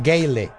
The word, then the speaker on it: Gale: ¿Brandon Gayo? – Brandon Gayo. – ¿Gayo? – Sí, Pato Casi. Le. Pato. Digo, Gayle. Gayle. Así se escribe.